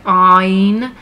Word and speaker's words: Ayin: It is pronounced in a guttural way. It should sound like Ayin, but mostly nowadays in modern Hebrew, we just pronounce it like Aleph, like A.